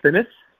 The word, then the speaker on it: DeFinis: It's DeFinis? – Yeah, see, I would butcher that.